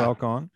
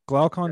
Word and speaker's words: Glaucon: I don't know if I can pronounce Glaucon Glaucon is right. – Yeah. Glaucon. – Glaucon yeah.